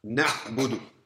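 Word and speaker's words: Na'budu (نَعْبُدُ): Na'budu. – Na'budu. – Na'budu. – Na'budu. – Noon. Fatha. Na. Ayn. Sukun. No vowel sound. Na'a'i'u. Nothing. Ba. Dhamma. Bu. Dal. Dhamma. Du. Connect it all. Na'budu.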